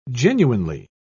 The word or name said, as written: genuinely